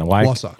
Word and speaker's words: Wausau: And other than that, give me the pronunciation again. – Wausau.